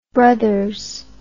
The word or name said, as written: bros